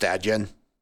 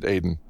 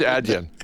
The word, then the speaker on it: Adyen: Should I tell you that it's pronounced Adyen? – No, which is why I'm continuing to pronounce it Adyen. But yeah, let's go with Adyen.